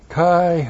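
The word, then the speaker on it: Kai (καί): Kai hutos. K-A-I and this H-O-U-T long O-S. Kai hutos.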